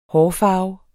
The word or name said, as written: hårfarve